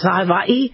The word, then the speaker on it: Hawaii: By the way, it's not Hawaii, it's Hawaii. The W is pronounced as a V, so it's Hawaii, but we say Hawaii because people don't like to say Hawaii, but they probably should.